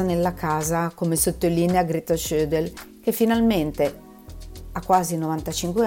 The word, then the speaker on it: Schödl: S-C-H-O coi puntini dell'umlaut sopra, come si dice in tedesco per indicare la metafonia, poi D-L. Schödel,